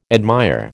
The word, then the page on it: admire: American English